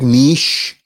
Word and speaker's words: niche: And it was a very, specific niche of things that I enjoyed doing in high school and people that I liked. – Hold on, time out. Bonus points for pronouncing it correctly. Niche.